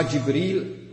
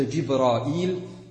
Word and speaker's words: Jibril: Und dieser Engel, das war Jibril alayhi salam oder Jibrail alayhi salam, wie man es auch aussprechen kann.